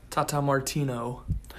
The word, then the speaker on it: Tata Martino: How do you say it? – Tata Martino.